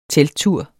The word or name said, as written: telttur